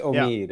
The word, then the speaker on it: Omid: It's Omid. Yeah. – Oh, really? – Yeah. It's a long E.